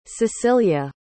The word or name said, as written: sicilia